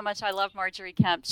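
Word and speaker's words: Margery Kempe: But in your book, we get a lot about Marjorie Kemp. Is that, if that's the way to pronounce it? – It is.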